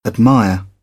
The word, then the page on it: admire: British English